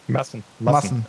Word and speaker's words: Massen: Massen. As if kind of like the liters of beer in Germany.